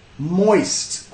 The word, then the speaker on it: moist: Ahí otra vez, consonant cluster, tiene que pegar la S y la T. Moist, moist.